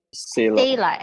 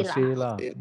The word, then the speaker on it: Saylah: How do you spell it? – Sela. – S-A-Y-L-A-H. – Oh, Sela.